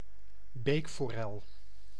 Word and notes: truite: /tʁɥit/; France

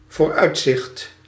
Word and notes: vooruitzicht: /vo:ɾ.ʌjt.zɪχt/

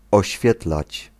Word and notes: illuminate: /ɪlˈlumɪneɪt/

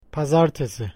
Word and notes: måndag: /ˈmɔndɑːɡ/; /ˈmɔnda/

